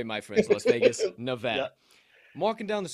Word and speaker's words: Nevada: You're already in the doghouse if you said the name wrong, but that's okay. It's Nevada, but fine. There you go. – I'm an Illinois guy. So okay, I hear you, but if you don't say it right here, they get pissed off.